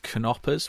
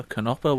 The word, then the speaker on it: Knopper: Just before this podcast, I treat myself to another one of those knoppers, which I've been actually told now you do pronounce the K. It's not a knopper, it's a knopper.